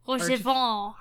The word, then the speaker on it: Rochefort: but it is Horshafond. – Orchifant? – Rochefort. – Rochefort? Yeah, that guy.